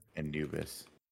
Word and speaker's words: Anubis: Anubis. We're saying that right? – Yeah, we're nailing it.